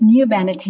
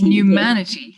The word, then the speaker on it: Numanity: And I particularly like the idea of, and correct me if I pronounce it wrong, Pumanity. – So it's Numanity. – Numanity. – Yes.